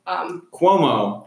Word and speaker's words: Cuomo: Cuomo.